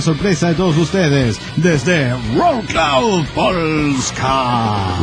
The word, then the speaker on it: Wrocław: Dice, hola amigos de la nave, les escribo desde la hermosa y siempre lluviosa ciudad de Wrocław, que por alguna razón se pronuncia Wrocław.